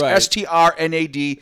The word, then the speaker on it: Strnad: I want to talk about the passing of Trevor Strad. I don't know how to pronounce it. I'm sorry. It's Strnad. He was the lead vocalist, singer, growlist for the death metal band Black Dahlia Murder out of Michigan.